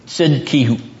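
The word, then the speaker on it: Zedekiahu (צדקיהו): Zedekiahu. Doesn't sound much like Zedekiah, but that's the way it would be pronounced in Hebrew.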